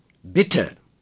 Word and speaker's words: Bitte: Bitte. – The double T shows that the I is short.